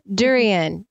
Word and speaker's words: durian: So we talked about before the show started, we talked about that picture of that new fruit, durian. Did I say that right? – Yes, you did.